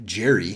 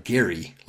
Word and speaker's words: Gerry: He says, he writes, in 1812, governor of Massachusetts and future vice president Elbridge Gerry, now again, this is me, he said it with a hard G, not like Jerry from Seinfeld, but Gary like in Indiana even though we say gerrymandering, the guy it's named after pronounced his last name Gary.